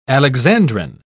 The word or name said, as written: alexandrine